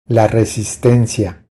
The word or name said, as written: La resistencia